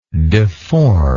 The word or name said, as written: deformer